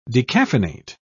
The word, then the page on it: decaffeinate: British English